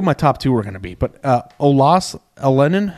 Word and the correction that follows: Olaus Alanen: how do you say his name? – I think it's Olaus Alanen, I think is how you say it. Olaus Alanen.